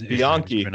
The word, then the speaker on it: Bianchi: So it wasn't all on you, unlike Joe Gisco or Simone Bianchi. Or Bianchi, as we now know, is Bianchi. Yes, we've been butchering that, unfortunately.